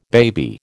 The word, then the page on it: baby: American English